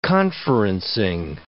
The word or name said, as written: conferencing